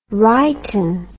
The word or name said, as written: righten